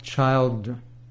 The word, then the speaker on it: Childre: That's from Doc Childra and Howard Martin. Doc Childre. It's spelled C-H-I-L-D-R-E.